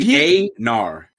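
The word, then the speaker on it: Einar: Just say A-Nar. – A-Nar. – A-Y-Nar. Don't even think about how it's spelled, bro. – Yeah, because in my brain, it's like... – It's E, but you want to say E, but it's A.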